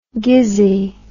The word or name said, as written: gizzy